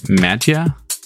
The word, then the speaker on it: Mattia: This is Saturday Jamming by Mattia Musella and Sequence. I butchered that first half, but... – It's okay. – M-A-T-T-I-A. How would you... Mattia? Mattia? – Yeah, Mattia.